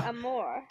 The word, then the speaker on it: amour: Amour. – Amour. I refuse to believe you're taking French lessons with that pronunciation.